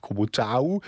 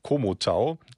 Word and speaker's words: Komotau: Es spricht sich auch nicht Komotau, sondern Komotau und liegt im heutigen Tschechien.